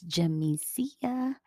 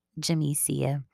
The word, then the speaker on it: Jamisia: And so, yeah, when I'm telling people my name and they need to spell it, I'm like, it's James I-A. So, Jamisia. And sometimes I say it kind of fast and don't hit the Mesia part. So I'm just like, Jamisia, Jamisia.